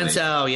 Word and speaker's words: Violento: I, I I think that you had talked about this before the violent Jack Takeda match recently. Violento, yes, Violento. – That was Sorry, a, I said it wrong.